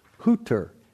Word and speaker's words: Hüther: It's Gerard, we would pronounce it Gerald Hüther, H U T H E R.